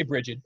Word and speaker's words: Brigid: And I wonder in crafting Brigid, I hope I'm pronouncing her name right. – I would say Brigid. I mean, she's an American, right?